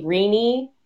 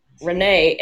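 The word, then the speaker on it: Renée: Like, my name in English would be Rini, because if you pronounce all the letters, but in French, because I have an E at the end of my name, it just means Renee, and just means feminine. – Huh. – So, like, the guy's version is R-E-N-E.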